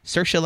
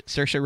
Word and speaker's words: Saoirse: I will be walking and I'll just be like, Saoirse like inertia. Saoirse like inertia. Those are her words from a video that I watched to learn how to say her name.